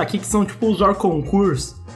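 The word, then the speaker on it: hors concours: Eu tô pronunciando errado, Fábio Faria? – Não, Orconcours, tá certo. – É, em francês... – Como que pronunciaria o Juninho Pernambucano? – É... – Orconcours! – Ah, agora eu entendi. Agora faz mais sentido, eu imagino que essa deve ser a pronúncia correta.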